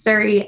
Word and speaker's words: Suri: So Sabina, S-A-B-E-N-A. And my last name is Suri, S-U-R-I.